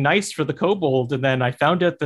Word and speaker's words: kobold: which is the kobold, I believe is how it's pronounced. Kobold, kobold.